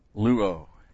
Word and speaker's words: luo: Little word, luo. This was, L-U long O.